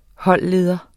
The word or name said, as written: holdleder